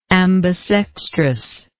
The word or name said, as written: ambisextrous